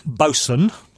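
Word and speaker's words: boatswain: Why is it written boxwain? – Because that's how they spell it, because nautical people do things differently. – Okay. – Bosun. – I've never in my life known that the word bosun was written boxwain.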